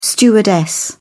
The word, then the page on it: stewardess: British English